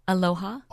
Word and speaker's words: Aloha: Aloha.